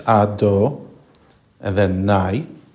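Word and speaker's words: Ado, and then Nai (אדני): Now he introduces a third name, which is pronounced in Hebrew the same way as Havaya. It's Ado, and then Nai.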